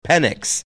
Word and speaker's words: Penix: No, the best part is the way it's pronounced is his last name is pronounced Penix.